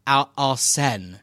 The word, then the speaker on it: Arsene: Remember, it was not Arsene, it was Arsene.